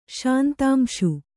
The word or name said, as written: ಶಾಂತಾಂಶು